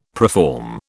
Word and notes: preform: British English

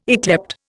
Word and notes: yclept: Indian

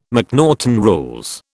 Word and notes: McNaughten rules: British English

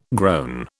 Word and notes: groan: British English

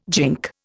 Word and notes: gink: Indian

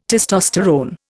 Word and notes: testosterone: Indian

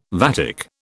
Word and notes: vatic: British English

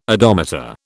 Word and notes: odometer: British English

